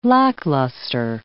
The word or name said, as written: lackluster